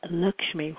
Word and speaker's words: Lakshmi: And her name, Lakshmi, which is pronounced to rhyme with luck, which is, I guess, not a coincidence. So it's not Lakshmi, it's Lakshmi.